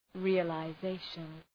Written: realization

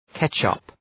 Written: ketchup

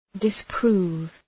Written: disprove

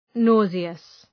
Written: nauseous

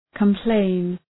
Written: complain

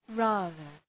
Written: rather

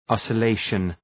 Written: oscillation

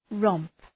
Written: romp

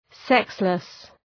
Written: sexless

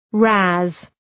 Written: razz

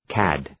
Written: cad

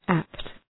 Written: apt

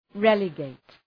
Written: relegate

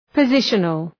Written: positional

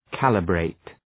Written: calibrate

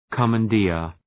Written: commandeer

